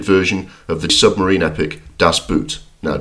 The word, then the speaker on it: Das Boot: Pronounce it Das Boot, with a slight German accent to add authority.